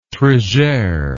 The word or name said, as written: trigere